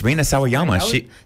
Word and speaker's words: Rina Sawayama: Slater, Rina Sawayama, Chemical Brothers. It's all happening right here on Sobe. – Rina Sawayama? – Yes, Rina Sawayama.